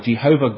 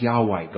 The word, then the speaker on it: Yahweh: And that's summed up in the very name he gave to the children of Israel, Jehovah God, or as it's better pronounced, Yahweh God.